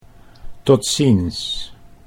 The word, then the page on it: tot ziens: Dutch